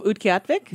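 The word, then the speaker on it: Utqiaġvik: A short film by Kimberly and Lloyd of, how do you say it again? – I think it was of Utqiatvik. – Yes, ma'am.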